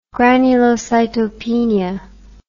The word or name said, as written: granulocytopenia